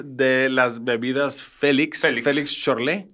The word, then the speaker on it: Schorle: Se escribe S-C-H-O-R-L-E. La C, digamos, es muda. Es como si fuera Chorlé S-H. Así claro. Se pronuncia.